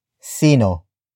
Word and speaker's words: sino: The first word is sino, which is spelled as S-I-N-O.